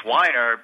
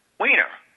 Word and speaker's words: Weiner: Take a look at my name, Weiner. Well, guess what? It's Weiner, but it would get pronounced as Weiner.